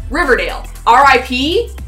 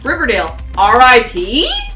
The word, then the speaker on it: Riverdale RIP: And how do I even pronounce this title? Riverdale, R.I.P.? Mm? Or is it Riverdale, R.I.P.? – I think actually you'll find it's Riverdale Rip. – Yeah, Riverdale Rip.